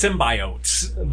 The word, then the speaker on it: symbiote: It's symbiote.